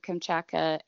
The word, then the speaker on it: Kamchatka: How do you say it? Kachachma or? – Kamchatka.